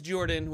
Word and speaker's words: Jorphdan: My name is Jordan with a silent PH in the middle, and I am joined always by my wonderful co-host, Sir Lucian, over there at Sir Lucian Gaming.